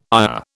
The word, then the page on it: ayah: British English